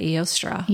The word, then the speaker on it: Eostre: It's E-O-S-T-R-E. How would you say that? – I would say Eostre.